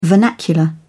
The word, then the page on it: vernacular: British English